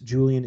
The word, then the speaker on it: Julian: Julian is spelled J-U-L-I-A-N.